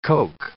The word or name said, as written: coke